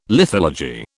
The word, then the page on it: lithology: British English